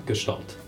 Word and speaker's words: Gestalt: Gestalt, is that how it's pronounced? – Yeah, Gestalt.